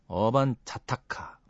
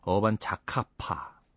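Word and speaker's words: 어반자카파: 어반 자카타, 자카파. 네. 읽을 때마다 틀려요. 어반 자타카, 뭐 이렇게. 어반 자카파.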